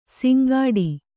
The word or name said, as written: ಸಿಂಗಡಿ